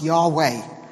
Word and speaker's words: Yahweh: YHWH, normally pronounced Yahweh in the original.